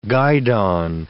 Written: guidon

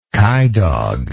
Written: coydog

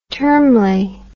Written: termly

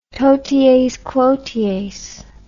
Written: toties quoties

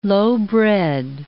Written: lowbred

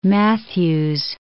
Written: Matthews

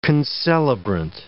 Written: concelebrant

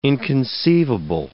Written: inconceivable

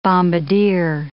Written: bombardier

